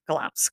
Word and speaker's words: Glamis: So if you look at the spelling of Glamis and you see how I'm saying it, they don't match. I want it pronounced Glamis because that's correct.